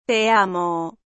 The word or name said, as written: te amo